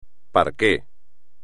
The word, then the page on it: parquet: espagnol